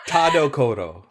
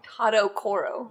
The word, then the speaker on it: Tadokoro: Is that how you say it? Ta- Tadokoro. – Tadokoro. Mr. Tadokoro. Koro. – Kuro. – Kuro. – Oh, in the Japanese version, it's Koro.